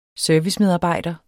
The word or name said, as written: servicemedarbejder